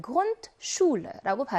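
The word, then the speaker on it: Grundschule: SCH가 들어가 있는 복자음 조합이니까 여기 나와 있는 SCH는 무슨 발음 내요? SH 발음을 내서 Grundschule라고 발음했습니다.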